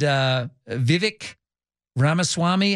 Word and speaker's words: Vivek Ramaswamy: And Nikki Haley and Vivek Ramaswamy. How do you pronounce his name? I still can't get the You said it correctly.